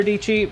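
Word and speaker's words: Dietschy: Her name is Sarah Dici, rhymes with peachy.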